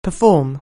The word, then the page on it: perform: anglais